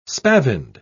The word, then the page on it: spavined: British English